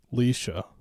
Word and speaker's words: Leisha: Is that how you pronounce it? Lysia? Lisa? – Leisha.